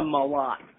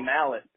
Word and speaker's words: Malott: Malott. – Malott? Is it Malott? I knew I'd say it wrong. I'll edit that out. – No need. No need. Everyone mispronounces it. Actually, most people say mallet, which I used to hate as a kid, but now I'm older.